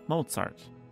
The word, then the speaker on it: Mozart: Two syllables, it's a noun or a name. Mozart. Remember to make that tss sound. Mozart.